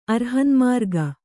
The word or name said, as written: ಅರ್ಹನ್ಮಾರ್ಗ